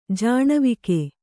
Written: ಜಾಣ್ವಕ್ಕಿ

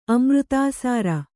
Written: ಅಮೃತಾಸಾರ

